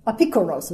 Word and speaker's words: Apikoros: Apikoros is how it's said in the Hebrew, which is actually stealing a Greek word.